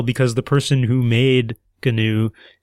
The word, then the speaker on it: GNU: You know, I've been pronouncing it GNU this whole time, where you pronounce the G.